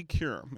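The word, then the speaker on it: Qerim: And so, Molly Kerim, is that's how you pronounce her last name, right? – Mm-hmm.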